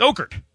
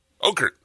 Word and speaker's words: Okert: You say it like there's an apostrophe in it. – Like I'm making him Irish. – You kind of bothered me. He's like Okert. It's Okert. – Okert. – There you go.